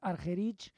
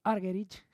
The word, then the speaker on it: Argerich: La verdad es que la pronunciación parece ser que es Argerich, pero bueno, pues en los países anglosajones suelen decir Argerich y cada uno pronuncia un poco como quiere.